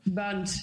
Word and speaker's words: bund: And it sounds like the little, how do you pronounce it, bund or bund? What's the bund? Yeah, we call it a bund.